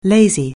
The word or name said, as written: lazy